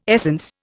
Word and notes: essence: Indian

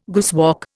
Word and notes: guesswork: Indian

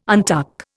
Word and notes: untuck: Indian

